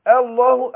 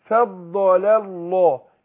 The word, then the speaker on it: الله: لكن في وسط الكلام هتسقط. اقول فضل الله. يبقى نطقت اللامين ورا بعض والهمزه في النص ايه تسقط. – يبقى انا هنطق اللامين ورا بعض. اللام الاولانيه مرققه اللي هي تفضل واللام لفظ الجلاله هتفخم عشان اللي هي اللام اللي قبلها ايه مفتوحه.